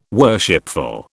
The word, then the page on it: worshipful: British English